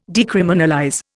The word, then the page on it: decriminalize: Indian